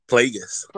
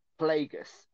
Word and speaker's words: Plagueis: So is it, hang on, how do you pronounce his name? – Plagueis. – Plagueis. Okay, Plagueis. So this is the speech that Palpatine gave very quickly.